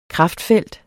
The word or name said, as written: kraftfelt